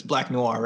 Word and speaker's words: Black Noir: but it's Black Noir, right? – Yeah, that's it.